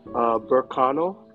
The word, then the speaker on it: Berkano: Berkano. – Ber, like B. – Yeah, B-E-R-K-A-N-O.